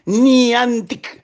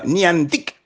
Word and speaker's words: Niantic: tiene que aprenderse bien el nombre de Niantic. Niantic. No sé si va a haber un acento en alguna. Niantic.